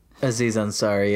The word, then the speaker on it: Aziz Ansari: Aziz Ansari, yeah.